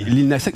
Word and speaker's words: Lil Nas X: Oui, Lil Nas X.